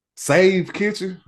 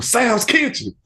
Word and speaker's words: Sav's Kitchen: At first, they said it wrong. They said something, Save Kitchen. I'm like, who the fuck is that? Somebody like, they talking about you, Sav's Kitchen.